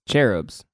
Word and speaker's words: cherubs: How do you say that? Cherubs? – Cherubs.